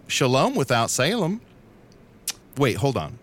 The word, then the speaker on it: Salem: Yeah. Salem. That's how you say it.